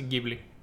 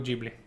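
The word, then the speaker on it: Ghibli: И се произнася гибли. Това е. Толкова. Толкова. Ако искате, творете и потрасете, ще попаднете на същото. Turns out, people just mispronounce it. – Сърцето ми е джибри, защото нямат нито Нека. Джибри пак е по-добре от джибли.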